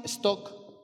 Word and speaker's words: Stock: En ese monte, San Simón Stock, así se pronuncia Stock, pero se escribe S-T-O-C-K, Stock.